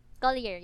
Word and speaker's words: Collier: Collier, yeah.